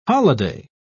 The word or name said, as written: holiday